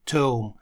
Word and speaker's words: tomb: Tome, гробница, tomb. Здесь буква B в конце не читается, только первые три буквы, tomb.